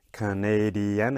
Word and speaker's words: Canadian: Now if we look at the nationality, this time we have Canadian. Again, four syllables. So which one gets the stress? Listen one more time. Canadian. Canadian. Okay? So in this case, the second syllable, the nay, the Canadian, yeah?